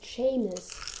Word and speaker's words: Seamus: Es ist richtig, aber du hast ihn falsch ausgesprochen. Du sagst Seamus Finnegan, er heißt Seamus.